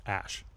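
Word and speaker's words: X Æ A-12: So Elon was on a podcast the other day and said that it's pronounced Ash.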